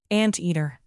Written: anteater

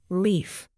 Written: lief

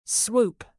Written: swoop

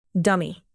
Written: dummy

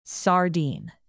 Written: sardine

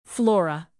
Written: flora

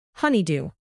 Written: honeydew

